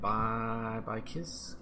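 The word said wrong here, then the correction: Bykski: Bixky. – Bixky, is that how you say it?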